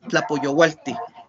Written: Tlapoyoualti

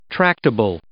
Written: tractable

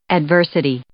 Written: adversity

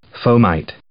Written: fomite

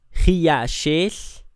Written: i'axel

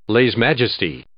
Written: lèse-majesté

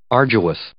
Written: arduous

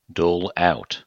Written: dole out

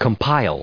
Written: compile

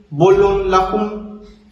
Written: bolonlajun

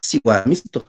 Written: siuamiston

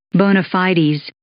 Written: bona fides

